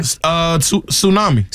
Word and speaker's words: tsunami: This has a silent letter in it. – Okay. – Tsunami. – Tsunami. – Tsunami. Yeah. – Tsunami. Sure does. – Now, some people might say tsunami. – Tsunami. – Tsunami. Somebody might say that. No. They'd be wrong.